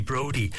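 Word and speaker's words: Broudie: I was at the top of the show saying we had a song from Ian Brody, and certainly Phoebe from Stratton was on to me to tell me that pronounced it wrong. It's actually Brody.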